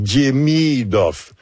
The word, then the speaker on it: Demidov: L'accent est sur la 2e syllabe. Donc, Demidov.